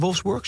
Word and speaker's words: Wolfsburg: is het hier bij Wolfsburg, zeg ik dat goed? – Ja.